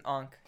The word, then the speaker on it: Ankh: Ankh. – It's an Ankh, yeah.